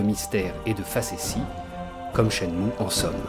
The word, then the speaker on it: Shenmue: espèce imaginée par Yu prononcer « Shenmue sûrement pour se rapprocher de la sonorité de Jianmu, alors que le kanji choisi par Yu Suzuki, différent du Hanze chinois de Jianmu, devrait se prononcer Sha-mu,